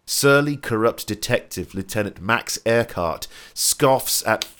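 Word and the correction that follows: Eckhardt: it's Eckhart. E- they don't say Earhart, Earhart. This, mate. They say Eckhart.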